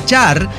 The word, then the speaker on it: txar: Concretamente de la palabra char, que se escribe T-X-A-R, ¿no? – Sí.